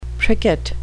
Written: pricket

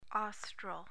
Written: austral

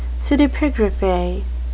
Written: pseudepigrapha

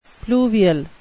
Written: pluvial

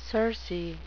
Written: circe

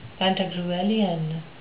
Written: pantagruelian

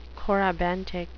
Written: corybantic

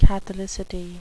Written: catholicity

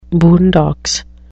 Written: boondocks

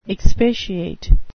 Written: expatiate

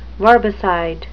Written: verbicide

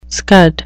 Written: scud

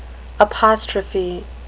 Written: apostrophe

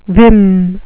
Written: vim

